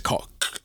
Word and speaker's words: Koch: No, it's Koch. – Right. – Like, you have to make that noise, and I don't mean any offence to any German listeners that I'm sure I'm not pronouncing it brilliantly, but it is.